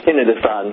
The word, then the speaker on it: tinnitus: Yeah, so tinnitus or tinnitus, both pronunciations are okay.